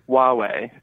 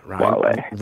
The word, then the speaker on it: Huawei: That is Huawei. – Huawei, yeah. – Keeping you active for all your favorite people. – Yeah. – Huawei. – Rhymes with Rahway, the town in New Jersey.